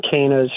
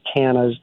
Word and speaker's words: cannas: And so, yeah, my mom used to get these, I always pronounced them cannas. I think she's corrected me many times, they're cannas, but they attracted the hummingbirds.